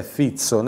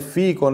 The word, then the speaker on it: Fico: Ecco, perché mi spiegavi che la pronuncia corretta è Fizzo, né Fico né Fizco, ma proprio Fizzo.